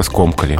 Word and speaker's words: скомкали: Но здесь без вариантов, здесь только скомкали. А все-таки, да? Ударение остается на корне и никуда не бегает. Скомкано, скомкал, скомкали, а здесь ударение везде на одном месте.